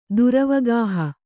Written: ದುರವಗಾಹ

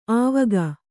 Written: ಆವಕ್

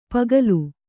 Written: ಪುಕ್ಕಲಿ

